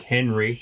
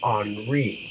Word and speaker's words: Henri: Gonna be trapped inside during hashtag Henry. – It's Henri. Say it like the news people.